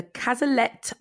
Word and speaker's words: Cazalet: So on to your second book for Bookshelfie, which is The Cazalet.